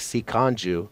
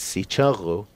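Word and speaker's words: Sicangu: I almost wish that it was a little more phonetically written, because it looks like Si Kanju, and it's Si Chungu, and the Oyate looks like Oyate.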